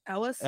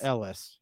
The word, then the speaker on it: Elwes: Is that how you say his name? – No, it's Elwes. – Elwes or Elwes? – Ellis? – Ellis, yes.